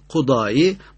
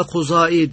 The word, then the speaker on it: Kudâî: İmam Kudâî Datla olunca bazen Kudai, bazen de Kuzai diye telaffuz ediyoruz.